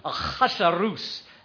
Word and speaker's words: Ahasuerus: In 486 BC, it's about 50 years after the Persians took over, in about 486 BC, King Ahasuerus, that's how you have to say it, King Ahasuerus came to power.